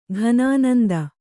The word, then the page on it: ಘನಾನಂದ: kannada